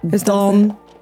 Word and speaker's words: Donegal: Jag vet inte varför du sa det med så konstig betoning. Donegal. – Det är precis så det är skrivet i fonetisk skrift. Donegal. – Hur stavas det? – Donegal. – Donegal. Vad tror du det? – Det var bättre.